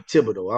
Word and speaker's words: Thibodeau: You know, I'm from Louisiana. It's supposed to be Thibodeau. I don't know why you say his name like that.